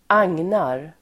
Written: agnar

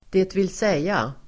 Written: dvs.

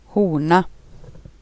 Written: fågel

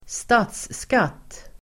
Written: statsskatt

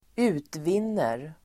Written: utvinner

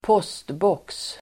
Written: postbox

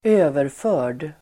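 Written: överförd

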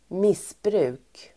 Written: missbruk

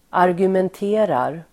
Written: argumenterar

